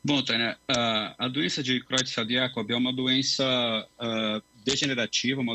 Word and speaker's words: Creutzfeldt-Jakob: É essa mesma a pronúncia para a gente? – É, Creutzfeldt-Jakob, Jakob. Tanto faz.